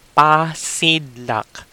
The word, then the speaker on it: pasidlak: We call that pasidlak.